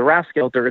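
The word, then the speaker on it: Rathskeller: So the building that we're using is I don't know if I'm saying this right, the Rathskelter. Is that how you say it, Bill? I assume it is. – Yeah. Yeah. – So the Rathskelter, it's a really old building.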